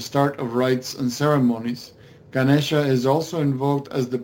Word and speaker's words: Ganesha: Ganesha.